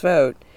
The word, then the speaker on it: Vogt: During Donald Trump's first term, Vogt, whose name is pronounced Vogt, did more than just perhaps anyone else to turn the president's demands and personal grievances into government action.